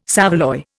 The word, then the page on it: saveloy: Indian